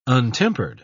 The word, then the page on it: untempered: British English